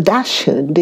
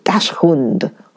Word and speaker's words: Dachshund: C-H-I-H-U-A-H-U-A. Chihuahua. Chihuahua. The Dachshund is D-A-C-H-S-H. U and D, Dachshund, Hund. I think it's a German word for dog.